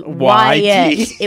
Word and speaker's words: Wyatt: There would be a bonfire and we White's would drink. Bottom. And it was I used to think it was white, like the color. It was Wyatt. It was W-Y-A-T-T, and just everybody pronounced it wrong.